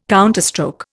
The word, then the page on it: counterstroke: Indian